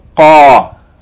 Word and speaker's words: Qaf: Please be careful not to pronounce Kha for Qa. Qa. Qa. Okay, that's the letter Qaf.